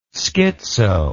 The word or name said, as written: schitzo